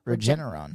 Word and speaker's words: Regeneron: Regeneron.